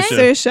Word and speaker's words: Saoirse: I read somewhere that Cersei... Wait, is that how you pronounce her name? – Saoirse.